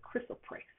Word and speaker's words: chrysoprase: And the stone that you can work with that goes along that Ascended Master Hilarion has you working with is the chrysoprase, That's how you pronounce it, chrysoprase.